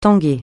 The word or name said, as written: tanguer